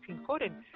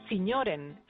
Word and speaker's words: Sinjoren: Sí, porque además, sí, claro, se pronuncia, si la lees tal y como, si la pronuncias tal y como la lees, es sinjoren, sí. Pero si la pronuncia, esto dicho en flamenco, se dice signoren, como si fueran señores,